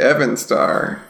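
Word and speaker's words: Eärendil: The Evan star. – The Evan. Evan star? Yeah. Okay. – Oh, yeah. I mean, maybe it's pronounced that way. – All right. – Evan, even. It's fine. Elves. – Yeah, yeah. – It's not pronounced that way, but okay.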